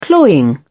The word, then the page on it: cloying: British English